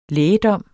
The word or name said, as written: lægedom